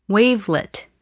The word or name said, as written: wavelet